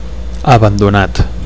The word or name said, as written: abandonat